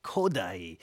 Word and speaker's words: Kodály: And interesting to think of that as a Hungarian rhythm in music, because it's almost like Hungarian language, where the emphasis is always on the first syllable, like Béla, Bartók, Zoltán, Kodály.